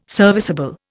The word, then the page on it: serviceable: Indian